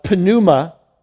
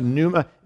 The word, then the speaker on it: pneuma: And that is an interesting, that's the word pneuma. You might say, well, isn't, wouldn't that be pronounced pneuma? There's no such thing in Greek as a silent letter.